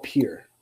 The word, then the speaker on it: Pyure: The other one is called Pyure.